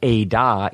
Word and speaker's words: edah: And so an edah, E-D-A-H, edah is a witnessing community.